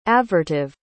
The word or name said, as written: avertive